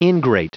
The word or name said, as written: ingrate